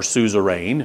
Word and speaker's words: suzerain: that great high king would now be called your suzerain or suzerain, if you want to pronounce it the old way, and you would be his vassal.